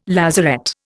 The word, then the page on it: lazaret: Indian